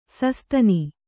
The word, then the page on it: ಶಶಾದನ: kannada